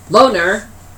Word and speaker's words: LONR: Loner, which is spelled L-O-N-R,